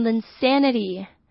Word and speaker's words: insanity: 是太疯狂是不可能发生 you're insane 那它在这边这个 insanity 呢是名词是疯狂的名词那我们通常这个词呢之前有一阵子林书豪很红的时候我们把它前面加了 L 变成 insanity insanity 就是那时候林书豪的狂热那这个字就是 insanity 跟 insane 要注意它的 SA 的 A 中发音不太一样